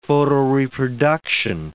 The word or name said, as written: photoreproduction